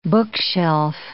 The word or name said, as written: bookshelf